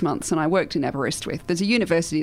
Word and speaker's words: Aberystwyth: It's Aberystwyth, which is a small town in the middle of Wales.